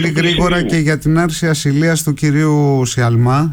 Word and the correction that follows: Σαλμά: έτσι δεν είναι? – Σαλμά, συγγνώμη για το, το γιώτα που εμφυλοχώρησε στην προφορά μου.